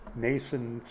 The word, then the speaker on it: Mnason: Mnason spelled with an M, M-N-A-S-O-N, a Greek spelling, from Cyprus, an early disciple.